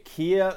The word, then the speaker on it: Keir: Keir Starmer, I think I am pronouncing that one violently incorrectly. K-E-I-R Starmer.